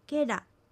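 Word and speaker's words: ケラ: けら、けらは味という意味です。アクセントはけにあります。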